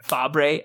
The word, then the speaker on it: Fabre: Fabre, F A B R E. I'm glad you pronounced it first. I was going to go there, probably Fabre.